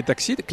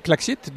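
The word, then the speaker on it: Klaxit: C'est piloté, c'est géré par l'agglomération des d'Épernay Oui, avec la société Taxit, Claxit.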